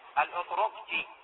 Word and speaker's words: الأطرقجي: وهناك كتاب أيضا تشبيهات القرآنية والبيئة العربية، هذه اسمها مجيدة، مجيدة الأطرشي، هكذا ينطقوا هذا الأطرق. ثلاث نقاط. الأطرقجي، مجيدة الأطرقجي.